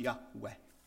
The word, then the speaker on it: Yahweh: And we've heard a Jewish, born-again Jewish speaker says it's pronounced Yahweh.